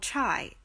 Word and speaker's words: çay: Turkish tea is called chai. It's spelled C-A-Y and pronounced just like chai, as in chai tea, and the word is derived from the C-H-A word for tea in Chinese.